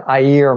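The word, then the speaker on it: Aïr: You also in Niger, they have the Ayer Mountains. It's spelled like air, A-I-R, but there's two dots on the I.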